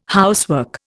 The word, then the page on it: housework: Indian